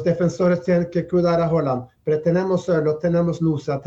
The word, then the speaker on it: Haaland: Es decir, Haaland, vamos a pronunciarlo como se pronuncia en Noruega, digamos, ahí la figura del arquero, pero hay toda una estructura de equipo fuerte, ¿eh?